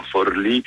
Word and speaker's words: Forlì: Ieri ero a Palermo, poi sono stato vicino ad Ascoli Piceno, adesso sto a Forlì, poi a Bologna scendo, prendo un altro treno e arrivo a Mondovì, quindi tutti i posti con l'accento sulla I.